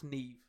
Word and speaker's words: Niamh: It's spelled N-I-A-M-H, but it's pronounced Neve.